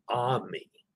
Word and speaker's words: Amy: It's spelled A-M-Y, but pronounced Ami.